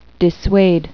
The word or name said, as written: dissuade